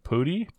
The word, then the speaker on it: Pudi: It's just Danny Pootie, please? – Danny spelled How do you spell that last name? – P-U-D-I.